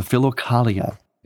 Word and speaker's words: Philokalia: Again, it's called the Philokalia. That's spelled P-H-I-L-O-K-A-L-I-A, Philokalia.